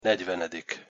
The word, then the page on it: negyvenedik: Hungarian